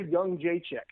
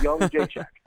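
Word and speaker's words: Jędrzejczyk: But, yeah, Joanna, it's actually Joanna Young J Chick, like Young J Chick. Those three, and it's the weirdest name ever. It took me a long time to actually figure out how to say it, but then I got the audio file of her saying it. It's Young J Chick.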